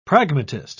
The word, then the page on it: pragmatist: British English